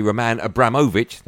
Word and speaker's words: Abramovich: Yesterday, the club released a statement confirming a final and definitive agreement, which will see Roman Abramovich, that's how you say his name properly, sell the club after 19 years.